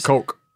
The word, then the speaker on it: Koch: Coke. – Coke brothers. Where I grew up, if it's spelled like that, it's Koch.